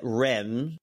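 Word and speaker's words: Reims: He's at Rem, which I believe is how you pronounce it. REIM. He is 21.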